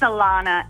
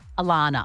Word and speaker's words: Alana: It's Alana. And it's I get double N-A-H. I get every letter of the alphabet put at the end of it, but it's just A-L-A-N-A.